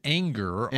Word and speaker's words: Ehinger: And we're hearing the pronunciation Anger. On Ehinger. Of course, he went to the University of Cincinnati.